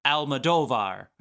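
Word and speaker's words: Almodóvar: It was produced by John's boy, Pedro Almodovar.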